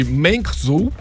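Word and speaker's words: Mengzhou (梦舟): A cápsula tripulada vai ser chamada de Mengzhou, que traduzido aí é navio dos sonhos.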